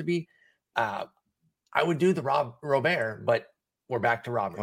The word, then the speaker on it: Robert: It's Robert. To Robert. Okay. And, you know, I just hope we just stick with this. I feel bad because I think it actually is Robert. And he asked people to pronounce it that way. I feel like people were on board and now it's back to Robert. The official pronunciation guide from the White Sox says Robert.